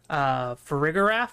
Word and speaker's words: Farigiraf: Farigiraf, I think is how you're supposed to pronounce it.